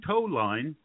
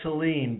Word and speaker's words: Toline: I read a lot of his stuff, and I'm very happy to welcome to the show Jordan Toline. Did I say that right, JM? – It's Toline, but nobody gets it right.